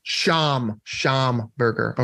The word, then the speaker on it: Schamberger: It's Josh Schamberger. – Schamberger. – Schamberger. Okay. Yeah. I can remember that. Like Schomburg. Yep.